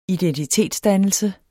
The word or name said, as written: identitetsdannelse